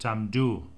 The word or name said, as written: tamdhu